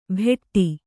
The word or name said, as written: ಭೆಟ್ಟಿ